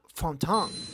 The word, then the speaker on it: Fontaine: O sea, se escribe Fontaine, pero como el francés se habla como si te pesaran los huevos, no hace falta pronunciar todas las vocales de una palabra, así queda Fontaine.